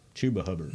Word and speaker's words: Chuba Hubbard: Chuba Hubbard.